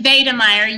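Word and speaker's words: Wedemeyer: So yeah, so I have to ask you, did I pronounce Maria's last name correctly? Maria von Wiedemeyer, or is it Wiedemeyer because it's German? – So it actually is Wiedemeyer, yes.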